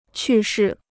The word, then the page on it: 去世: qù shì